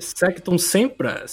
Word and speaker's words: Sectum Sempra: Letra C, Sectum Sempra.